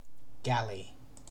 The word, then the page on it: galley: British English